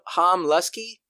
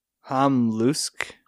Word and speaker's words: Ham Luske: Is it Ham Luke or Ham Ham Lusky? – Ham Luske.